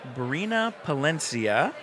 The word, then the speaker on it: Brina Palencia: We have Brina Palencia.